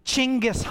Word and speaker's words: Chinggis: That's where I discovered that we've been saying it wrong the whole time. His name is actually Chinggis Khan.